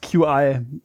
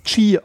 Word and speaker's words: Qi: Best QI. Wir müssen es ab jetzt Qi aussprechen, weil das sagt die englische Qi.